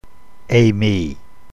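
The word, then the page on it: eimi: New Testament Greek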